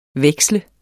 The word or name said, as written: veksle